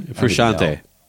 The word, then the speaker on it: Frusciante: But anytime that John, uh, did you say it, Fruscetti? Is that how you say his name? Frusciante. Frusciante. Thank you.